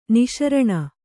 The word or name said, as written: ನಿಶಾರಣ